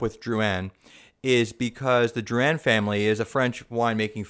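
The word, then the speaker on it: Drouhin: Well, it's Drouin, and then it's Bourgogne. But it's okay. Here's the deal. – Bourgogne? – Yeah. Here's the deal. – Like G-U-N? – Closer to that than gone or yeah.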